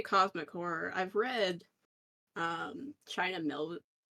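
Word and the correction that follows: Miéville: is that how you pronounce it? – Mieville.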